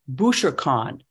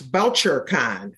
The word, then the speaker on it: Bouchercon: including tell us about Boucher Khan. And am I saying it correctly? – No, it's Boucher Khan.